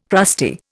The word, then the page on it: crusty: Indian